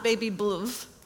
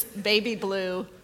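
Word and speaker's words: Baby Blve: But blue is spelled B-L-V-E. It's not Baby Bluv. It's Baby Blue, but with a V.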